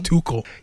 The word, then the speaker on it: Tuchel: I think I called him Tuchel the last time. It's Tuchel? Pronounced Tuchel.